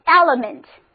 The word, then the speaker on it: element: 那么在英文中同样有一个专门的词根来表示要素、元素，就是 element， element，它本身也是一个单词，重音放在第一个音节上，首字母一发 a 的音，第二个一发 e 的音，第三个音不发音，跟我读就是 element。